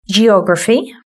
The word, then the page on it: geography: British English